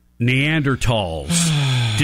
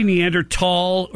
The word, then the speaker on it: Neanderthal: Neanderthals. Did he say Neanderthal or did he say Neanderthal? – I doubt he said it right. I doubt it. – You're supposed to say tall.